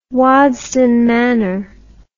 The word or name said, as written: Waddesdon Manor